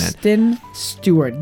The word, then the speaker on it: Kristen Stewart: But the thing about that is you wouldn't have Kirsten Stewart. – Stewart. – The end of the saga of me saying her name wrong is coming to an end. – Kristen Stewart. Give her the respect she deserves.